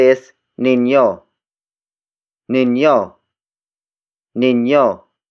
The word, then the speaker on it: ninyo: The next word is ninyo. Ninyo. Ninyo. Ninyo is spelled as N-I-N-Y-O.